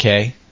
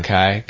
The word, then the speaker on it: K: I guess that's how it's pronounced. K. – Okay. – Not Kai.